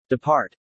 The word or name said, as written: depart